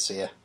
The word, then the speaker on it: Sia: I think it's Sia. – Sia? Okay. It's spelled like, S-I-A.